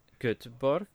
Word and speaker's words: Göteborg: Although I have to admit that when we went to Göteborg, is that the way to pronounce it? Mm-hmm.